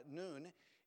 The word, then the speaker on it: Nun: And by the way, I've always pronounced that Nun, and I had it pointed out recently, that's actually Nun.